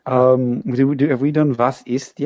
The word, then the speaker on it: was ist: And it's pronounced was ist. – Was means what. Was ist is more like what is.